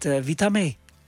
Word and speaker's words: Witamy: Maria, ik begin met Witamy.